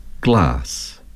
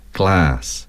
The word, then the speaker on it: glass: The British would say glass, and Americans would say glass, grass, grass, fast, fast, past, past, bath, bath, and half, half.